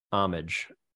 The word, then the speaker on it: Homage: Homage. – Homage. Mm-hmm. Soft H. Okay. – Not homage. It is okay. Not an homage to something.